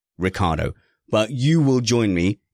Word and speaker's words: Ricciardo: And I think, Matthew, that Daniel Ricciardo, and I said it right, I did.